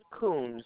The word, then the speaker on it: Kuhns: My name is Marilyn Coons. The last name is spelled K-U-H-N-S.